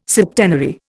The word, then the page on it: septenary: Indian